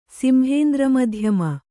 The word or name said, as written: ಸಿಂಹೇಂದ್ರಮಧ್ಯಮ